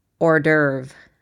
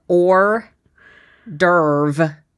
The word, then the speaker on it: hors d'oeuvre: hors d'oeuvre. That's how we say it here. Or d'oeuvre. hors d'oeuvre. Yep. How do you say it? Hors d'oeuvre. Hors d'oeuvre.